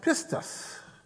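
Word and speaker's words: pistis (πίστις): It's pronounced pistis.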